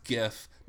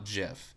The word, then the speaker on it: GIF: it's pronounced GIF, not JIF.